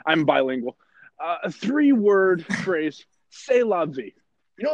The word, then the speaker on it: C'est la vie: C'est la vie. C-E-S-T-L-A-V-I-E. It's a French word.